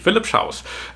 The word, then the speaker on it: Philipp Schaus: Neben Uwe Julis ist jetzt auch, korrigiere mich gerne bei der Aussprache, Philipp Schaus, ist das richtig ausgesprochen? – Ja, richtig, wie der deutsche Schaus sozusagen, genau. – Wie der deutsche Schaus, okay, Philipp Schaus.